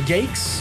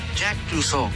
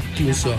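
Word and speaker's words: Jacques Clouseau: ¿Pero cómo se pronuncia? ¿Jakes? – No, la neta, no, quien sepa el nombre que nos siga y nos corrija, si te puede dar la pronunciación, mucho mejor. – Se llama Jack Clusot. – Ah, ya ves, Clusot.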